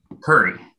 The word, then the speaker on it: Coury: Curry.